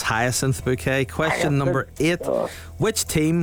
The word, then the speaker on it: Bucket: It's bouquet. B-U-C-K-E-T. Bouquet. The accent on the second syllable.